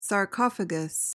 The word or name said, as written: sarcophagus